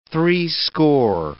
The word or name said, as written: threescore